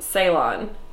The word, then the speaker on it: Ceylon: Ceylon. Not like Cylon. That's like a sci-fi thing.